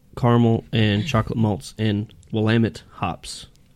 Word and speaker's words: Willamette: No, that's Willamette, apparently. – Willamette. Depending on which homebrew shop you go to, it's a different pronunciation every time.